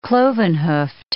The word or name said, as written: cloven-hoofed